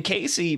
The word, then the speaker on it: Caisy: C A I S Y. Casey like Daisy.